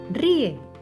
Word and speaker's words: ríe: La palabra ríe lleva su bate de béisbol en la letra I. La palabra ríe separada sería ri-e.